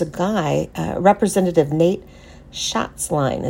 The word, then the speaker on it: Schatzline: Nate Schatzline.